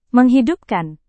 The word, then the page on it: menghidupkan: Indonesian